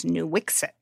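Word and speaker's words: Nuiqsut: The official English pronunciation that the state of Alaska has is Nooksit.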